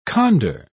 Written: condor

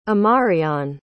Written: amarion

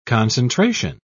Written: concentration

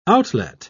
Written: outlet

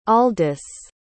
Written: aldus

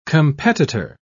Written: competitor

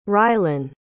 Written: rylen

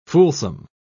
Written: fulsome

